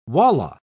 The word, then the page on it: walla: British English